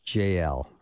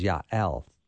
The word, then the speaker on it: Jael: Now, when I started this interview, I called her JL or Jail. So I've been corrected twice. The first one was JL, and the proper Hebrew pronunciation is Yael.